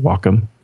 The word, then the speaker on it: Wacom: Is that how you pronounce it? Wacom? – Yeah.